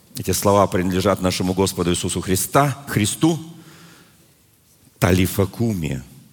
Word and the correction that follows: талифа куми: Или если говорить по правильно ставить ударение, тали, талифа куми. Вот так это произносят.